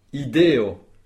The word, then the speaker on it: ideo: Une idée, très bien, il y a trois syllabes. Ideo, et vous avez bien, tous les trois, accentué la lettre E. Ideo, l'avant-dernière syllabe. Instrui.